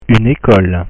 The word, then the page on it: une ecole: French